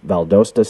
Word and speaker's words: Valdosta: You've got an associate degree in pre-law from South Georgia College, a Bachelor of Science degree in Health and Physical Education from Valdosta State University.